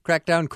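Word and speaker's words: Craicdown: Crackdown is actually spelled C-R-A-I-C, which is the Irish word for fun. Crackdown calls itself World Acousticana,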